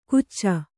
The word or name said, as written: ಗುಚ್ಚ